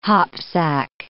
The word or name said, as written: hopsack